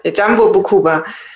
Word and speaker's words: Bukoba: We're going to start, and your charity is Jambo Bukoba. Did I say that right? – That's right. Jambo Bukuba.